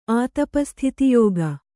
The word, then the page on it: ಆತಪಸ್ಥಿತಿಯೋಗ: Kannada